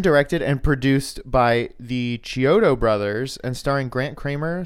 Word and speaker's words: Chiodo: His name, I assume it's spelled C-H-I-O, Chiodo, Chiodo. – C-H-I-O-D-O.